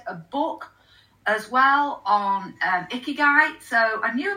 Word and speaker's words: Ikigai: It's Ikigai.